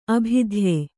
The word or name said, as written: ಅಭಿಧಾಯಿ